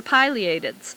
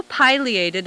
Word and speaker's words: pileated: Although pileated is the first pronunciation given in Webster's New World Dictionary, pileated is also listed and is equally correct.